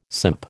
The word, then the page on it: simp: American English